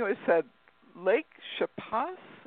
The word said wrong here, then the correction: Chapala: Is that right? How do you say that? – Lake Chapala. Lake Chapala.